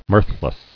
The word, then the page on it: Mirthless: mirth·less; Mirth"less